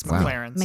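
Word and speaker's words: MacLaren's: McLaurin's Pub. PT. – It's How I Met Your Mother, and you're pronouncing it incorrectly. It's McLaren's.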